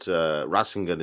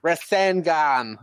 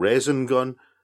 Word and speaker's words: Rasengan: Rasengan. – See, the way they pronounce it, it sounds like they're saying raisin gun.